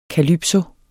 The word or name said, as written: CALYPSO